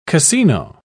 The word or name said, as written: cassino